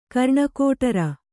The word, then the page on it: ಕರ್ಣಕೋಟರ: karṇakōṭara